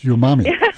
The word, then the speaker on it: umami: Mama, it's umami. – Yes, umami. Yeah. You know, this one's not really that new, but it's becoming more popular as Asian cuisine, which is full of umami,